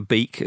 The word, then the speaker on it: van der Beek: But again, uh, at, at, the time of recording, Donny van der Beek or Bake, uh, some people forgive pronunciation. I'm going to go for Beek at the time being.